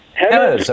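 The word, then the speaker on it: Jemez: Hemes Springs, New Mexico. – Hemes. – How do you say it? – Hemes.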